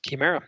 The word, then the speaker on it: Chimera: how that's prepping us for this age of Chimera. Chimera? Did I pronounce that right? – Yeah, that's correct. Chimera. Yeah.